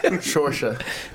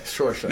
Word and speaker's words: Saoirse: Shorsha. S-A-O-I. How's it? – S-A-O-I-R-S-E. – Shorsha.